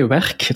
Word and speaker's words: Werk: And for those who wants to know, Work, you spell it like twerk, but without the T, so that's a good start. And in Dutch, it also means work. We spell it the same way, werk. It's called werk.